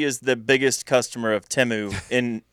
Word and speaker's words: Temu: I went to see my mom the other day, and she had a new order from, which I now know it's pronounced Temu, all the commercials on the Super Bowl.